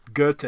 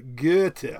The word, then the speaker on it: Goethe: She said, pronounce it, think about an O, but don't pronounce it. Say O-O while thinking intently about the letter R. But don't pronounce the R. So Goethe. I can't do it.